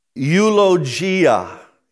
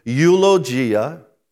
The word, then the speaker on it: eulogia: It comes from the Greek word eulogia, eulogia. Now, as I say that Greek word, and I am pronouncing it properly, eulogia, you should be hearing an English word. Because eulogy, from that Greek word, we get our English word eulogy.